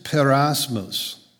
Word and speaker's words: peirasmos: The Greek phrase here for various trials is poikilos perasmos. Poikilos means various or diverse. And perasmos, which is spelled P-E-I-R-A-S-M-O-S, perasmos means temptations.